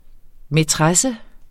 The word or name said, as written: maitresse